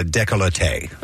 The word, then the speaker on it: décolleté: It's a décolleté. – Ooh. – Décolleté is how you pronounce that area.